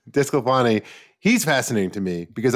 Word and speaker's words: DeSclafani: You mentioned is and I, I, can never say his name right. De, Desclafani is that Anthony Desclafani? – Desclafani.